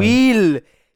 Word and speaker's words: Wheel: Wheel! Wie das Rad.